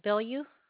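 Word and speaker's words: Bilyou: Last name is B as in boy, I-L-Y-O-U. – Okay, thank you. You just pronounce that Billu? – Yeah, yep, you got it right.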